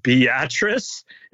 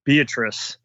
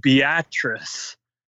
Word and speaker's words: Beatrice: Beatrice is how you say it. Just looking at it, it looks like Beatrice. But they keep saying it, Beatrice. That's, I guess, how it's properly said in Nebraska.